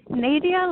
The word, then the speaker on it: Nadia: I'm Nadia. Hi. – Lydia or Nadia? – Nadia, like Nadia, but with the long A.